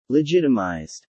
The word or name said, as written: legitimized